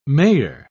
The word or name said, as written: mayor